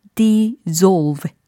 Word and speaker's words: dissolve: dissolve라고 발음이 되는 단어는 d-i-s-s-o-l-v-e 라는 철자예요. 그래서 용해하다 라는 의미의 단어입니다. 발음 차이 다시 한번 들어보세요. 어, 자격이 있다. deserve. 용해하다. dissolve. 네, 그래서 특히 명사 부분의 발음 차이가 살짝 난다라는 겁니다. 철자상에는 차이가 그래도 좀 있는데 발음상에는 명사 부분이에요. deserve이냐, dissolve이냐 요 차이입니다.